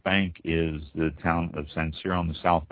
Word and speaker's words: Sancerre: And Sancerre, S-A-N-C-E-R-R-E, Sancerre.